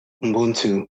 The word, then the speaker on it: Ubuntu: I think in some of the a prep that I had for talking with you, I came across, I'm not even sure I'm going to say this correctly, Ubuntu? – Ubuntu. – Ubuntu. Okay, yeah.